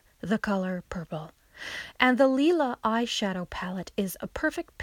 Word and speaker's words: Lila: It's spelled L-I-L-A, but it's pronounced Lila.